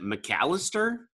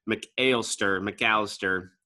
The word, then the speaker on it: McAlester: I just on the brewery itself, they've got 3 separate locations, all of them in Oklahoma, 2 of them in, is it McAlester? Is that how you pronounce it? – Yeah, sounds right.